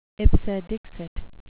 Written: ipse dixit

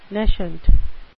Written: nescient

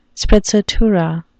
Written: sprezzatura

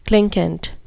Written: clinquant

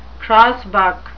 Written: crossbuck

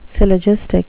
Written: syllogistic

